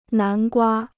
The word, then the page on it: 南瓜: nán guā